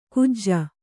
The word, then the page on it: ಕಾಜ: kannada